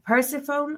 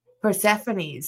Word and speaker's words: Persephone: Who just won a championship tonight in RevPro, a Scottish championship, so she's collecting them all, versus Alex Windsor, versus Persephone. I don't think I ever say that right. – Persephone, sorry.